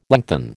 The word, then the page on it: lengthen: American English